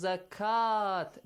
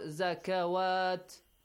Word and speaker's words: zakat: So it will be pronounced as zakat, not zakawat.